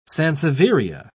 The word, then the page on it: sansevieria: British English